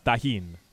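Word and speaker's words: Tajín: And it's spelled like T-A-R-J-I-N-E or something crazy. – Right. Oh, that's the brand. But the actual spice itself is tajin. – Tajin. – No, tajin.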